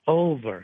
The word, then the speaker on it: Olver: It's Olver, like I don't even know, Olver. – Olver. – Olver, yeah, right. – O-L-V-E-R. – Right. It's Oliver without the I. – Yeah, O-L-V-E-R.